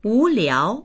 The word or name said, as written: wu2liao2